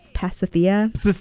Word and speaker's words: Pasithea: Pasithea. Pasithea. – Is it P.S.? – It's the name of a goddess. – P-A-S-I-T-H-E-A.